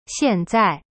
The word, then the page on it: 现在: xian zai